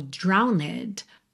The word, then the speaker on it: drowned: Se escreve como drowned, mas você não pronuncia essa letra E.